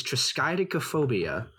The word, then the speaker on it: triskaidekaphobia: That word is triskaidekaphobia. Right, triskaidekaphobia. I'm emphasizing that because there's another staff member that believes it is pronounced triskaidekaphobia. It is not.